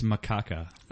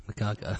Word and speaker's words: macaca: It's pronounced macaca. – Oh, okay, sorry. – Yeah. – Macaca.